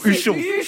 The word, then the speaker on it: Huchon: J'ai l'impression d'être un mélange d'Anne Hidalgo et de Jean-Paul Houchon. Ça fait longtemps qu'on n'avait pas cité Jean-Paul. D'ailleurs, je l'ai mal dit, c'est Houchon. – Huchon.